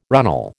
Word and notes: runnel: American English

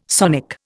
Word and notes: sonic: Indian

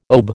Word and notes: OBE: American English

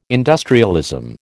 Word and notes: industrialism: American English

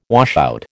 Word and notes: washout: American English

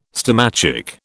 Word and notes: stomachic: British English

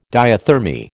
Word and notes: diathermy: American English

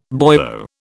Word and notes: boyo: British English

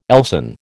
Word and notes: Elsan: American English

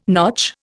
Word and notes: notch: Indian

